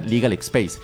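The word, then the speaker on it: LegalXpace: En Facebook nos pueden encontrar como Legal X Pase con C, Legal X Pase, se pronuncia Legal Space.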